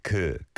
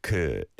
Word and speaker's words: 그: The 그 meaning like that, those words, those. 그, 그. It's a light vowel sound, not an oo. And you didn't go quite to 그, but it sounded a bit less light than a 그.